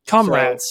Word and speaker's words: Comrades: And so it's like, okay, he needs to do UTMB, now Comrades, and Comrades. So, it's not, there's not an extra syllable at the end, though.